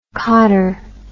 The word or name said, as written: cottar